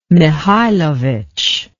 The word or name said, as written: mikhailovitch